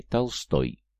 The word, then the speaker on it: Толстой: Праправнук рыцаря Индроса получил от великого князя Василия Темного прозвище Толстый, или, как тогда говорили, Толстой, с ударением на последнем слоге.